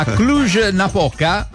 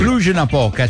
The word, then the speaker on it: Cluj-Napoca: Di stretching a questo punto, perché a Cluj-Napoca, si pronuncia così, Cici. – Cluj-Napoca, città della Romania, c'è un modo molto semplice per viaggiare gratis sugli autobus, e cioè fare gli squat.